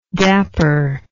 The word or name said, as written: dapperness